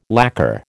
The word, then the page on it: lacker: American English